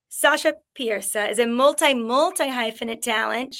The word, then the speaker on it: Pieterse: I'm your host, Holland Roden, and I am so excited to announce this week's guest, Sasha Pieterse. Am I pronouncing this correctly? That was a no. The shake was a no. – No, it's so close. It's Pieterse.